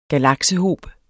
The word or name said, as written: galaksehob